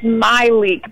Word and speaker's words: Myleik: You were close. It's Myleik, but that's okay.